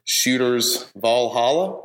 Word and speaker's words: Shooters Valhalla: It is Tuesday around lunch, and I'm in downtown Columbus at Shooters Valhalla. Is that pronouncing that right? – Mm-hmm.